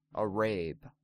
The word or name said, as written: Arab